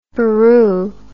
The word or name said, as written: buroo